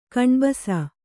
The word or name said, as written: ಕಣ್ಬಸ